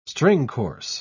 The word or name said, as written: stringcourse